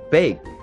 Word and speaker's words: bake: 其实你听到这个字 bake, 那个 K 如果读的稍微弱一点的话，它跟我们的烘焙的焙几乎是一模一样的发音， bake, bake。